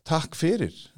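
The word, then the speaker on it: takk fyrir: Is that how you say it? – Tak fyrir. That's almost there.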